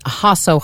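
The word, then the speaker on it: Jasso: Yes, and on Instagram, I'm Hasso Hustle. I spell that in a way that I think helps people pronounce my last name.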